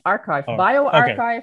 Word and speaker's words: bioRxiv: Archive, BioArchive, okay.